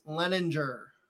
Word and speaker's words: Leininger: And today we have a special guest, Dr. Chris Leininger.